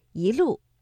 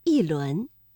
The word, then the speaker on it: 一: 正确的声调应该是第一句的“ 一路霜林”，我们的“一” 读二声，一路。第二句“ 一轮明月”，这里的“ 一” 读四声，一轮。